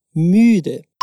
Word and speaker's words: müde: A próxima palavra também tem esse som de U umlaut, e o significado é cansado. Wiederhole bitte. Müde.